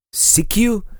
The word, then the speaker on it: Sekiu: I'm coming to you today from this town called, and let me see if I can pronounce the name correctly. It's Sikiu. I guess, S-E-K-I-U, very close to another town called Clallam Bay.